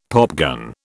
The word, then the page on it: popgun: British English